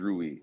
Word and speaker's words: Rui: So as we're currently constructed, you know, and obviously with the do we pronounce his name Rui? It is Rui.